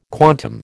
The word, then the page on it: quantum: American English